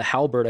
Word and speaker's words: halberd: The halberd, I think we're pronouncing that right. Is that right? – Yeah, that's correct.